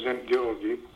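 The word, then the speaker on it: Szent-Györgyi: There's a chap called Zent Georgi who discovered vitamin C, who recommended the use of vitamin C and iodine.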